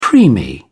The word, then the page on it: preemie: British English